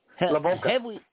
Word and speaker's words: Lavoca: Is it Lavoca? – Uh-huh. – All right. – Rhymes with polka. Lavoca.